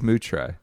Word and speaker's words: Moutre: It is Moutre. – Moutre. – Yeah, like a cow, Moo. Moutre.